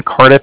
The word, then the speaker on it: Cardiff: Okay. – And I was calling with the Bank of Cardiff, and Cardiff is spelled C-A-R-D-I-F-F, as in Frank, so it's like car and then different.